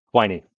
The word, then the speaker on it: Quiney: Quiney. It rhymes with whiny. Yeah, you got to have a whiny sounding last name if you're going to be a lord.